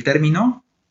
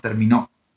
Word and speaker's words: término: So if you say el término, then they're going to understand something different if they're talking about término and not termino.